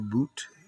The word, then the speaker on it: Bout: Turns out we have a guy in our prison by the name of Victor Boot, B-O-U-T, pronounced Boot.